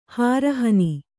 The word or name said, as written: ಹಾರಹನಿ